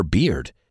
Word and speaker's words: beard: Sabes pronunciar cerveza, ¿no? Beer. Hasta usáis la palabra birra. Pues ahora solo tienes que añadir una D al final. Y voilà, tenemos beard. Piensa que si bebes rápido, tendrás beer into beard.